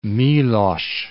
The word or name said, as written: Milosz